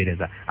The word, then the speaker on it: Reza: সেটা হচ্ছে আমার আইডি ফেসবুকে আছে রাগবি রেজা, রাইট? – আমি বানানটা বলে দিচ্ছি আবার, RADBI D4 ঢাকা, RADBI রাগবি, REZA রাগবি রেজা।